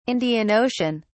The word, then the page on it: Indian Ocean: British English